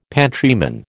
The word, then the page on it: pantryman: American English